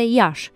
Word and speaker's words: Iași: În primul rând, din cauză că localnicii, ca toți moldovenii, nu pronunță înmuiată nici consoana ș, forma curentă în vorbire este Iași, deși se scrie Iași cu i final.